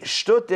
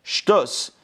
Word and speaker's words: shtut: It means shtut is, or in Ashkenazic pronunciation, shtus.